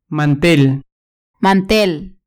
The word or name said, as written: mantel